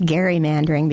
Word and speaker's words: gerrymandering: Actually, it would be appropriately pronounced gerrymandering because it's after Elbridge Gerry,